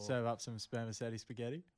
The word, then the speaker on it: spermaceti: What was it called again? Spermetti. – Spermaceti. – Spermaceti. – I'm probably saying that wrong. It's spelled S-P-E-R-M-A-C-E-T-I. So it's sounds either like spermaceti or spermaceti. Mm.